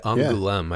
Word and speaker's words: Angoulême: The Eagle Award, the Angoulême Prix de la Critique. – That's pretty good. Yeah, Angoulême, yeah. I believe is how it's pronounced.